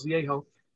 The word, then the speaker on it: Vallejo: which you see today is Vallejo, but they pronounce Viejo, put him under arrest and created the California Republic.